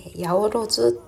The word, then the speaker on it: 八百万: 八百万というのは 800 万と書いて八百万と読むんですが、えー、この意味はたくさんの神様という意味になります。